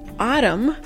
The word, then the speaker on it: autumn: Si las palabras fall y autumn significan otoño, otoño, si autumn se escribe A, U, T, U, M, N. Se escribe un poco raro, pero se escucha de esta manera. Autumn, autumn. Repítelo una vez más autumn, autumn y fall.